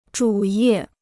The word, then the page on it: 主页: zhǔ yè